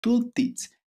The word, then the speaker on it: tooltips: tooltips. ¿Lo pronuncio bien? Sí, tooltips